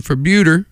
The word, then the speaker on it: Febueder: Next up, there's a band called Fabuter, which is a terrible name, but I like their sound. The band name is spelled F-E-B-U-E-D-E-R, and according to the Facebook page, it's pronounced like Bermuda with an F.